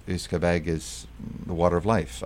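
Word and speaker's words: uisce beatha: The original pronunciation was Iscabeg, which means the water of life.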